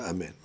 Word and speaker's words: amen: Okay, so the amen, you have to pronounce the nun, ketufa.